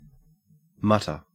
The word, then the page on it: mutter: Australian English